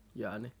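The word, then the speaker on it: Hjørne: Og, og jeg ved ikke, om man skal udtale hans øh, navn lidt anderledes, men det staves altså H-J-Ø-R-N-E, e, Jørne. Så tænker jeg, det er sådan, man skal udtale det.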